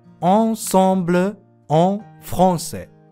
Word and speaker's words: ensemble en français: さて、アンサンブル・アン・フランセですが、フランス語で正しく発音すると少し違う発音になることをご存知でしたでしょうか ensemble en français